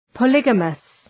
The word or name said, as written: polygamous